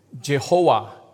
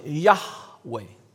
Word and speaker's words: Yahweh: 到了后期，已经没有什么人知道怎么正确来去念这个 Y H W H，但是近代的学者他们认为呢，这个 Y H W H 不应该把它把它发音成 Jehovah，应该把它比较发音成 Yahweh。